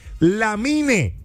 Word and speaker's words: Lamine: El nombre es Lamine.